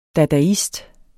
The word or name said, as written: dadaist